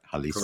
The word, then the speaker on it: Jalisco: Jalisco.